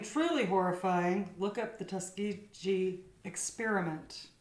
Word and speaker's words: Tuskegee: Yeah, the Tuskogee Airmen, yeah, that black air group, yeah, that looks awesome. – It says really Tuskegee.